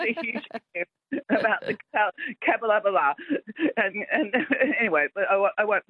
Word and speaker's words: Capalaba: And it took me ages to learn how to say Capalaba. – Oh, well, it might not be my show you're enjoying then. – Because that's how, if you look at it, it's C-A-P-A-L-B-A, Cap-L-Laba.